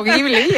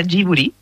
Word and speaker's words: Ghibli: De hecho, yo digo Estudio Ghibli, y aquí lo solemos llamar Ghibli, lo escuchábamos también, ¿no?, en ese reconocimiento cuando anuncian el premio Princesa de Asturias, y se pronuncia Ghibli, entonces. – En japonés se pronuncia Ghibli. Porque ellos no tienen el sonido de la L, pero es una historia bastante graciosa, porque el nombre lo elige Hayao Miyazaki, y lo elige por la palabra japonés.